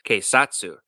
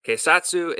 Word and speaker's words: keisatsu: Spirit Spirit Today's Lessons is going to be the Japanese word for police, and that is keisatsu. Keisatsu is spelled K E I S A T S U.